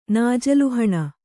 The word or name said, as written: ನಾಜಲುಹಣ